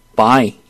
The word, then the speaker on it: buy: UY인데 이건 I라고 발음을 해줍니다. 그래서 buy.